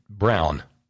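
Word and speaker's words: Braun: The junior senator from Indiana's name is pronounced, according to Dave from the Blind, Brown. Just like Adolf Hitler's wife, Eva.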